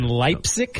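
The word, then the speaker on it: Leipsic: In Leipzig? – Right. Yeah, that's how you pronounce that.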